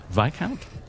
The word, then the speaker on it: Viscount: Viscount? – Remember, discount Viscount. – Discount. It doesn't make any sense. – Yeah, that doesn't help. – I remember because it doesn't rhyme with discount.